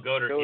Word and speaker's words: Goedert: Goedert. – Oh, Goedert, yeah.